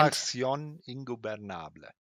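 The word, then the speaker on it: La Facción Ingobernable: Da steht Private Party gegen Outrunners, gegen FTA oder House of Black, gegen La Faction Ingobernable. Ach, LFI, ich kann es nicht aussprechen. Oder erklärt. – La Faction Ingobernable.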